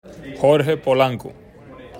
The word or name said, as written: Jorge Polanco